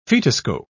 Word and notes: fetoscope: British English

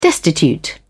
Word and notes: destitute: British English

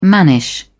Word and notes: mannish: British English

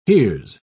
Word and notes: here's: British English

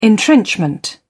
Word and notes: entrenchment: British English

